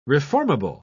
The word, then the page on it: reformable: British English